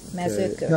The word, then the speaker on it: Mezőkövesd: K-A-L-O-S-C-S-A. – I'm sorry. And Mezőkövesd, that is the highland area. – Mezőkövesd.